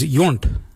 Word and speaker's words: yoornd: Mira is spelled M-I-R-D-A. The next colour is yunt. Yunt is yellow. Yunt. Yurnd is spelled Y-O-O-R-N-D.